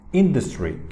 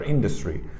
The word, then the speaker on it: industry: E, Industry kelimesi. Industry kelimesinde şiva vardır. Yani ikinci hecede ı sesi çıkar. Industry.